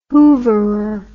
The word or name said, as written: hooverer